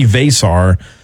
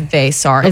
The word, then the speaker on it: Veesaar: The Hawks did pick up another player in round 2 yesterday, Henry Vasar. I believe I'm saying that right. That's how ESPN was saying it this morning. It's spelled V-E-E-S-A-A-R. He is French. – The pronunciation guy says Vasar. – Okay, good. – V-E-H in all caps. – Okay. – And then dash S-A-R-R.